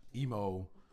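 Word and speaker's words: Imu: what's his name? – Emo. – Right? – Emo. Yeah. – Whatever. – Rhymes with emo.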